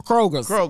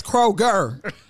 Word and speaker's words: Kroger: Kroger's. – Man, run to Kroger's and get me some bacon right quick. – She said y'all gonna pronounce it right today. It's Kroger. – Kroger. Get it right.